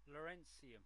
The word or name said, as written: lawrencium